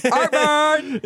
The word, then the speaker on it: Art Mern: Art Mern. It sounds like heartburn. – Yeah, a little bit like heart. So if you're wondering how to say his name, say heartburn and then take away the H.